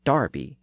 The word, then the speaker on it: Derby: And the word spelled D-E-R-B-Y is Derby in the US, but is Darby in Britain.